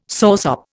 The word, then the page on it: soursop: Indian